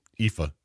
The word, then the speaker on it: Aoife: And then something called Aolf, which is pronounced Aoife.